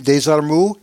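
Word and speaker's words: Desormeaux: I believe that's the French pronunciation of his last name, Desarmoux.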